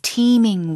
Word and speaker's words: teeming: It's teeming with people, teeming with students. – 그러니까 뭐 우리 팀, 너희 팀 할 때 T-E-A-M 하고 발음은 똑같아요. – 네. – 스펠링은 다르지만 T-E-E-M인데 동사로 북적북적 되답니다.